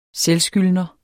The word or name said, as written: selvskyldner